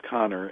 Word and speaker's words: Kanner: And so, by the way, it looks like Kanner, but it's actually pronounced Connor.